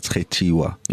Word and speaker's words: Khetiwa: So when I say my social media, I say it with as Kheti, because it's spelled like that. So it's a pedi name. It's Khetiwa.